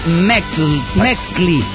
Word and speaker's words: Metzli: Metzli Luna. Se pronuncia Mextli.